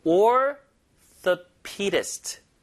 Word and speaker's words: orthopedist: Orthopedist. 자, 제 발음 잘 들어보세요. Orthopedist. 힘을 얻어주세요. Pedist.